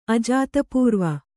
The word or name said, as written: ಅಜಾತಪೂರ್ವ